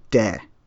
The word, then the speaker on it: De: So, one of the ideas I learned about in Taoism is the concept of, I think it's called De, or pr- spelled T E, pronounced D E.